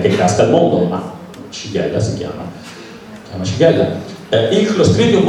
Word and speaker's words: Shigella: Poi c'è la Shigella, si scrive Shigella, ma si legge Shigella.